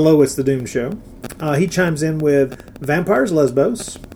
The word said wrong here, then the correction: Vampiros Lesbos: Vampiros Lesbos, I should pronounce it correctly.